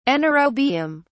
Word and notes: anaerobium: British English

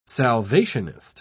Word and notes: salvationist: British English

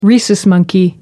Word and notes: rhesus monkey: British English

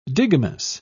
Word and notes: digamous: British English

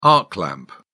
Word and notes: arc lamp: British English

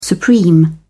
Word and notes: supreme: British English

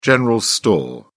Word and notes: general store: British English

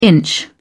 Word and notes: inch: British English

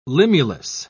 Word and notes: limulus: British English

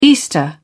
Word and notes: easter: British English

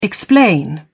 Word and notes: explain: British English

